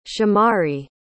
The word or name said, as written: shamari